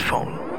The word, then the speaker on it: Phaune: It's spelled P-H-A-U-N-E. But it's pronounced phone.